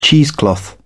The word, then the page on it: cheesecloth: British English